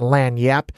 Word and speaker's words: lagniappe: It's actually spelled LAGNIAPPE, but it's pronounced Lan Yap, and it's like a slang phrase for, and Creole slang phrase for something, a little something extra.